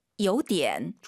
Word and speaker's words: 有点: 얼화를 빼도 돼요. 빼면 근데 발음이 요디엔입니다. – 요디엔.